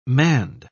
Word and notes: manned: British English

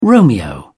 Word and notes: romeo: British English

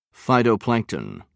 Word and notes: phytoplankton: British English

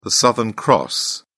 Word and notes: southern cross: British English

